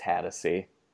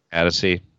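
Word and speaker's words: Hatosy: Oh, that stars Sean Hatosi. From Alpha Dog. – I believe it's pronounced Hattacy. – Hattacy.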